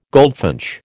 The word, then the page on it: goldfinch: American English